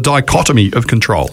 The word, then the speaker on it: dichotomy of control: So, Belinda, talk us through this thing, and correct my pronunciation if I muck this up, is the dichotomy of control. Is that how you say it? – Well done.